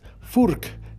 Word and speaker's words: Furch: Some of the people we interview were the CEO of Furch Guitars, but they pronounce it as Furk Guitars.